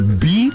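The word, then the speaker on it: BYD: O cara que vende carro, ele vende carro e ele fala BID. Não é B-I-D, é B-Y-D. – Ah, eu adoro quando fala inglês. – Porque o Y é a pronúncia, é o nome, é como se fala a letra Y em inglês. – Exatamente. Belo Horizonte. – Né? – Exatamente. – B-Y-D. O cara que vende B-Y-D e fala B-I-D, já não compro dele.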